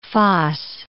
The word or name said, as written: foss